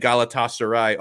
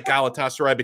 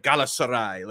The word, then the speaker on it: Galatasaray: And so, like, it looks like Galatasaray, but he was like Galatasaray. Like, the way he said it was like, wow, I'm way off on this.